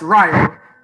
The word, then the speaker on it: Rayo: First of all, it's Rayo.